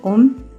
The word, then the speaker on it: Om: Se pronuncia Om, pero en realidad se escribe Aum, A-U-M, y esto es debido a que el análisis de la letra O en sánscrito representa la conjunción de las letras A y U.